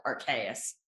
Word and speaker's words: Arceus: Is it pronounced Arceus? because I said Arceus. – No, keep it so rolling. – It's totally valid. I actually had to like listen to a YouTube video about this because apparently, I think it's like in the Japanese version, it's pronounced with the soft C, I believe. Don't quote me on that. But in the Americanized version, they call it Arceus.